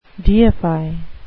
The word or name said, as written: deify